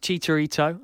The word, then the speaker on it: Chicharito: Chicharito, is it? – Chicharito. – Yeah. – Hold my hands up. Like, it's Chicharito, okay?